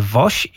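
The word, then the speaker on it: Wos: Pisze się Woss. Nazywa się Woś i jest z pochodzenia Polką, więc bardzo serdecznie gratulujemy.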